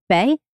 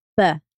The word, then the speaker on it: Beth: So the birch tree in the Ogham alphabet, it is Beth, like it's pronounced, or sorry, it's written Beth, but it's pronounced Bay or Buh, depending on who you're talking or who's saying it.